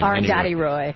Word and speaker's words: Arundhati Roy: all those Howard Zinn and Chomsky and Arundhati Roy. Oh, sorry if I'm mispronouncing the name. – Arundhati Roy.